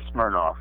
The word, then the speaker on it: Smirnoff: And I'm going to be dancing amongst them, along with, because of San Francisco, a superstar drag queen, Countess Katya Smirnoff Ski, or Sky. She pronounces it like the vodka, which she drinks.